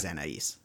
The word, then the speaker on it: Anais: Anais is probably how you say it. – Anais is the pronunciation, yes.